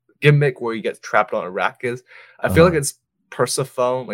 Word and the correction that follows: Persephone: like the story of Persephone. – I'm so sorry, guys. Persephone.